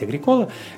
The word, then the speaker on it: Агрикола: Агрикола. Можно произносить Агрикола. Это и так, и так позволительно.